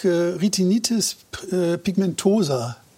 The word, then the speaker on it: Retinitis pigmentosa: So, jetzt nächste Erkrankung, Retinitis pigmentosa. Habe ich das richtig ausgesprochen? – Ja.